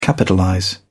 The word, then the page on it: capitalize: British English